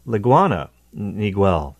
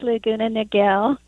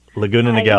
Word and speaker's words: Laguna Niguel: In California, in Laguna excuse me, I said that wrong. Laguna, Laguna Niguel. I totally butchered that, didn't I? – Yeah, it's Laguna Niguel. – Laguna Niguel.